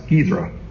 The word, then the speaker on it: Cizre: That's spelled C-I-Z-R-E, but pronounced Gizra, on the basin of the Tigris River.